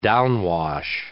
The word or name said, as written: downwash